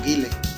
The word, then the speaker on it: Guile: El tema de Guile y el tema de. ¿Cómo se dice? ¿Guile o Gail? ¿O Guile? – Pues aquí en México se le dice Guile, ¿no? – Pues Guile, que lanza el Alex Boom.